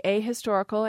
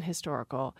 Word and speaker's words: ahistorical: We've got speakers who say ahistorical and anhistorical. The issue is whether you pronounce the H at the beginning of that word.